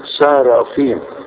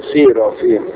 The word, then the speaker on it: صارافيم: على فكرة اسمها صارافيم، في ناس كتير ينطقوها سيرافيم، هو مش سيرافيم، صارافيم اسم جمع ومفرده صاراف، وكاروبيم او شاروبيم اسم جمع ومفرده كاروب.